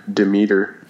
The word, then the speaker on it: Demeter: There's no N in there, so I guess it's just Demeter.